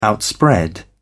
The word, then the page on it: outspread: British English